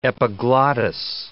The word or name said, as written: epiglottis